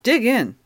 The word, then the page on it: dig in: American English